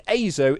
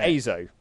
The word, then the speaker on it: Azo: And the A in Azo is pronounced just like the letter A. Like, ache and apron. So yeah, Azo.